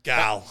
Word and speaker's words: Gaul: A lot of the other places, Gaul, kind of didn't. – Which is how my name is pronounced, but not spelt. – Gal. – G-A-U-L.